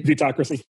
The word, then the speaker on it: vetocracy: Vetocracy? – Vetocracy.